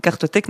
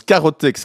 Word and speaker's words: carothèque: Cartothèque nationale. – Vous avez entendu, elle prononce carothèque, ce qui est juste, mais elle se dit, faute de frappe, je corrige direct derrière, cartothèque. Ha !